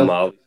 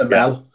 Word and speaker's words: Amal: It was Amal. – Amel. – Amel. – It's spelled Amal, but it's pronounced Amel.